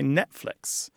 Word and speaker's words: Netflix: But the number of people who pronounce the T in Netflix approaches zero. Most Americans, at least, or North Americans who speak English, say Netflix.